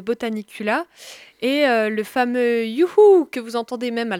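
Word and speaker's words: juchu: youhou, donc en tchèque, ça signifie hurrah parce que si je vous l'épelle, ça s'écrit pas comme nous on l'écrit en français, c'est J-U-C-H-U. u c h u et ce mot-là veut dire hurrah en tchèque.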